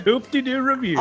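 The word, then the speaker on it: Hoop-Dee-Doo Revue: Hoop-De-Doo Review.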